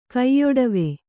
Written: ಕೈಯೊಡವೆ

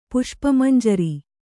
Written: ಪುಷ್ಪಮಂಜರಿ

